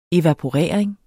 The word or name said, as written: evaporering